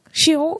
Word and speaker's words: Sheol: I don't know if I'm pronouncing it right, but you get, right? The Sheol, the S-H-E, the S-H-O-E-L. Sheol, I think. Sheol is the pronunciation.